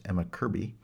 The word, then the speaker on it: Emma Kirkby: He's joined by Emma Kirkby, or as I have heard it's pronounced, Emma Kirby, and Carlos Mena, a countertenor.